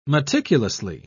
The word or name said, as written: meticulously